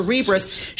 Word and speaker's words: Cerebras: Call the company. How do you say your name? Like Cerebral. Yes. So Cerebras shares soaring.